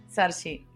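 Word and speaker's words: Saoirse: Sí, Sargi Ronan. Sargi. que se escribe Saorsi.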